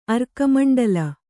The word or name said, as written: ಅರ್ಕಮಂಡಲ